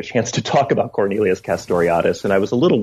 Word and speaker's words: Castoriadis: And here you're drawing on the work of the Greek scholar Cornelius Castoriadis, if I'm pronouncing it correctly. – Castoriadis, yeah.